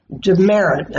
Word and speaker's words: Demerit: And we're talking to the CEO, Laurie Demerit, and she said, that's how it's pronounced, Demerit. Yeah. Like mm-hmm. The teacher gives you.